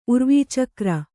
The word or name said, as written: ಉರ್ವೀಚಕ್ರ